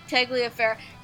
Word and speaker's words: Tagliaferro: I think it's Tagliaferro. – Tagliaferro. That sounds about right.